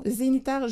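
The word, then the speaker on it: Zeniter: Donc, c'est un roman Zénitaire, je crois que ça se prononce de cette façon, Zenitaire, une romancière française.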